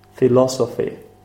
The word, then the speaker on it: philosophy: So, what happens here is that you have the stress on philosophy. – Uh-huh. – Philosophy. So it's on the second syllable.